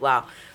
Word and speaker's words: Lowe: I've been following him since college because My uncle's name is Brandon Lowe, spelled the same way, but he pronounces it Lau.